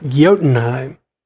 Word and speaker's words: Jotunheim: Did I say that right? – Jotunheim. – The Odenheim? I would always think that Odenheim would be spelled with an O. – It's Jotunheim.